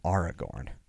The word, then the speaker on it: Aragorn: And I was looking at it and I'm like the way we pronounce Aragorn is a very English thing, like an English language thing. AR isn't technically air in Elvish. Mm-hmm. It would be pronounced R. So I thought, oh, it's not Aragorn. It's Aragorn.